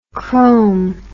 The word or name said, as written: crome